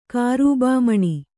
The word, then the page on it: ಕಾರೂಬಾಮಣಿ: kārūbā maṇi